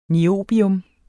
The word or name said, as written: niobium